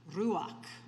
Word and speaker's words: ruach: just one word, ruach. Ruach. If you say it right, it blows wind.